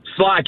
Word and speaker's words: Schley: Schley County. Yes, did I say it right? – Sly County. – Sly County. Man, I'm going to get that one right. – Yes. Sly County. Think like the Sly Fox.